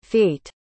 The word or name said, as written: fait